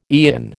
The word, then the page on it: aeon: American English